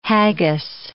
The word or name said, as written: haggis